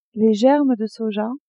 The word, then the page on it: germes de soja: female